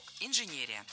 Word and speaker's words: инженерия: Ударение в этом слове должно падать на третий слог – инженерия. В качестве шпаргалки предлагаю обратиться к однокоренному существительному «инженер». Инженер – инженерия. Преобразование слова на «ия» ударение не меняем.